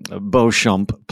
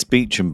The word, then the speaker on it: Beauchamp: It's Beecham, by the way.